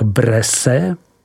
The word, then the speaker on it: Bresse: Nadmořská výška vinohradů zasahuje až do 400 metrů a nalezneme je ponejvíce kolem toku řeky Ajny v takzvané tabulové části, tedy v rovinách Bresse, vyslovuj Bres, které jsou od nepaměti známí zemědělskou činností, vinohradnictvím, ale také výrobou prvotřídního sýra Bresse Bleu, vyslovuj Bres Blue.